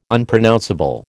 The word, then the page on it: Unpronounceable: American English